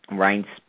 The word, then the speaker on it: Reince: The head of the Republican National Committee, Reince Priebus, I still can't pronounce the guy's name. Why would you name your kid R-E-I-N-C-E?